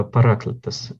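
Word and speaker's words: параклитос (παράκλητος): Э, интересно, что на греческом, здесь вот неверное ударение стоит, я не посмотрел, э, параклитос должно стоять, да?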